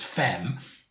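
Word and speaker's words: F3M: The name, it wasn't until I think the UMK itself when they said their name, because it's spelled F three, the, the number three, M, uh, but pronounced fem.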